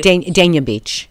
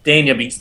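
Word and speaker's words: Dania Beach: Danya Beach. – Danya Beach, thank you.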